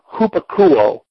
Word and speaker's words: hupakouo: This is the noun form here in Romans 1:5, but this comes from a Greek verb, hupakouo. It's transliterated H-U-P-A-K-O-U, long O.